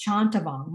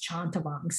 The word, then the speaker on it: Chanthavong: And our last name was Chantavong. My maiden name is Chantavong, C-H-A-N-T-H-A-V-O-N-G.